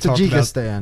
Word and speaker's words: Tajikistan: I'd say it's time we move on to the lodge in Karakistan. How do you say that country? – Now that we've Tajikistan.